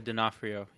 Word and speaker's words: D'Onofrio: Yeah, D'Onofrio.